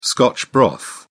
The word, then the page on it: scotch broth: British English